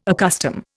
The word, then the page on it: accustom: Indian